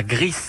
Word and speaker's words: Gris: Eh bien, on joue à Gris. Alors, ça s'écrit G-R-I-S, gris comme la couleur donc, mais on prononce le S car c'est de l'espagnol.